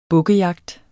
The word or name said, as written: bukkejagt